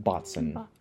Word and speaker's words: boatswain: It's spelled boatswain, but they always pronounce it botson.